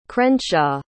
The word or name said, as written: crenshaw